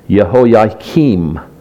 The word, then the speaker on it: Jehoiakim: And his name is pronounced Jehoiakim.